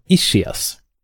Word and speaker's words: ischias: Den officiella förklaringen är att han har ischias. Säger man ischias, är det så det uttalas? – Ja, faktiskt.